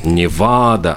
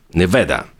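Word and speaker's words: Nevada: И поскольку происхождение этого штата э, от испанского слова «невадо», «невадо», что «снежный», вот те, кто живут в этом штате, очень недолюбливают э, как бы, ну, вот это испанское произношение и говорят э, средний слог коротко, не растягивают. Обычно там «невада», а тут «неведа».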